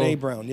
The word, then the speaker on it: Brené Brown: I think it's Brené Brown, who's a researcher. – Yes. – Have you heard of her? I'd never heard of her until... – Brené Brown, yes.